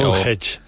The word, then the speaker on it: Stonehenge: Stonehenge, sí.